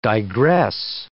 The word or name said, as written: digress